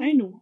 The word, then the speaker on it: Ainu: Ainu, einfach.